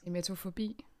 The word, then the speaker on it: emetofobi: Øh, og så er der noget, der hedder emito, emitofobi, tror jeg det udtales. Emetofobi? Ja, Ja.